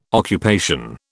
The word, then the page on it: occupation: British English